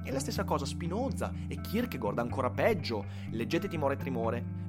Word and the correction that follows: Timore e Tremore: eh, che, scusatemi, Timore e Tremore, eh, che è un è un mezzo scioglilingua quando lo si pronuncia velocemente. Leggete Timore e Tremore